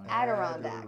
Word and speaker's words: Adirondacks: The Adirondacks. – Adirondacks.